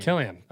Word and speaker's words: Cillian: Killian. – Killian. Okay. Killian, confirm. – So in the shot when Killian Murphy stands in the middle of crowd...